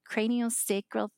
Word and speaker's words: craniosacral: Did I pronounce craniosacral correct? – You did. That was perfect.